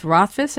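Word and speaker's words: Rothfus: It's now Connor Lamb versus an incumbent Republican, Keith Rothfuss, I think is how you can pronounce his name. – That's right.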